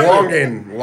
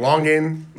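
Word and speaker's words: longan: You said L-O-G? – Don't do that now. L-O-N-G, like long. – Like long, and then A-N.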